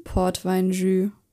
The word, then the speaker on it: Portweinjus: Da gab es die Lende vom Wittelsbacher Weidrind in Portweinjus. – Spricht man das so aus? – Ja.